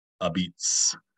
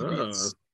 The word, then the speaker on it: apizza: and it's spelled a pizza, APIZZA, and if you go to the authentic ones in the New Haven area, but they pronounce it a beats, with a B sound instead of a P and no A sound at the end, so it's a beats, and you get mozzarella on it.